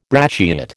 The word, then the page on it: brachiate: American English